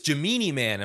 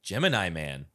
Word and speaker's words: Gemini: and I said, ooh, it's Gemini Man, and my dad walks by and goes, Gemini Man. Never mispronounced it after that.